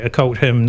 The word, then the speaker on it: Occult Hymn: Okay, Occult Hymn, not being devilish, it rhymes with Adult Swim, obviously, is where they got that from.